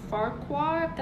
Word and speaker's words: Fuqua: Antoine Fuqua.